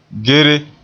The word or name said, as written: gere